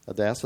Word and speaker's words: Adassa: Adassa, did I say that right? – Very well.